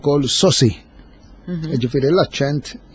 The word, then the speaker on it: saucy: Sewa, mela, sosi għandna l-aċċent ħalli nkunu eżatti fuq l-ewwel sillaba, sosi, ġejja mill-Ingliż sosi, S-A-U-C-Y, li tfisser l-ewwel nett wieħed li jidher smart u li jista' joqgħod jitkessaħ ftit.